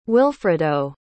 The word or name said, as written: wilfredo